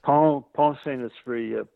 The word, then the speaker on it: Pont-Saint-Esprit: Pont-Saint-Esprit, right? Yeah. – It's Pont-Saint-Esprit.